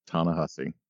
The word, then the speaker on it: Ta-Nehisi: Ta-Nehisi? Okay. – Ta-Nehisi. – Well, I remember because it sounds like hussy.